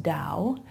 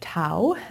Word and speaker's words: Tao: And so it's, as you can see on your screen, pronounced Tao instead of Tao, as it might look.